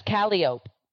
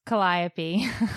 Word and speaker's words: Calliope: Because I thought it was Calliope. – No, it's Calliope.